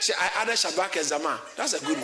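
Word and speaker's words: Shabach: It's called, it's spelled S-H-A-B-A-C-H. S-H-A-B-A-C-H. How do you say it? – Shabbat.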